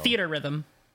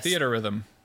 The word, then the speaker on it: Theatrhythm: Yeah, I don't know how to pronounce it still. – Theater Rhythm. – It's Theater Rhythm? – Yes. – Theater Rhythm. – Yeah. Okay. It's just like how it looks.